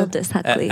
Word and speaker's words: Aldous Huxley: Okay, what he wrote is, what Orwell feared, George Orwell, he's kind of comparing and contrasting George Orwell's 1984 with Aldous Huxley. Is that how you pronounce it? – Aldous Huxley.